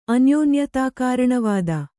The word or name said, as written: ಅನ್ಯೋನ್ಯತಾಕಾರಣವಾದ